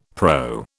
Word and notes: PRO: British English

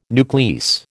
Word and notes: nuclease: American English

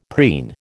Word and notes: preen: American English

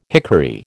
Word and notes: hickory: American English

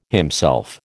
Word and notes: himself: American English

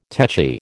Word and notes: techy: American English

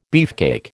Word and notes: beefcake: American English

